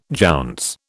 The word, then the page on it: jounce: British English